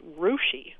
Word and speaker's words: Russia: There's a little town not too far from Lima, and it's spelled R-U-S-S-I-A. – Russia. – But it's pronounced Rushi.